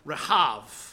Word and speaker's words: Rahab: Because we're all fluent in Hebrew, the word for Rahab the person, if you were to pronounce her name, as I understand it's supposed to be pronounced, is more like Rahav. Whereas the Rahab that is the enemy would be Rahav. You know, the emphasis on the proper syllable changes.